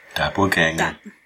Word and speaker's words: Doppelganger: So Eric says, I just listened to the Doppelganger. – Doppelganger.